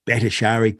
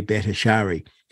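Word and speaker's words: Bhattacharya: Of course, you follow Peter McCullough and all Jay Batter. I can never pronounce his name. – Battershari. – Good. Thank you. I might have that forever now. Jay Battershari.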